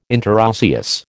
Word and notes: interosseous: American English

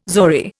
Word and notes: zori: Indian

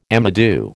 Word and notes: amadou: American English

